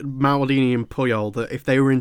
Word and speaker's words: Puyol: He's just like, I hope I'm saying that right. Puyol, is it? – Carlos Puyol. Puyol.